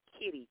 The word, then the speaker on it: Kitty: No, I was going to say my name is Kitty, K-I-T-T-Y. – Oh, I'm sorry, K-I-T-T-Y.